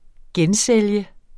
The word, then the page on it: gensælge: -ˌsεlˀjə